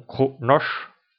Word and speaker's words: Kronos: Kronos, qui se prononce en klingon Kronosh, la planète d'origine des klingons.